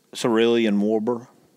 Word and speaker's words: cerulean warbler: Cerulean warbler.